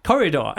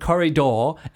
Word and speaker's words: corridor: Corridor. – Okay, so you say the word corridor, ending with door. And he always says corridor.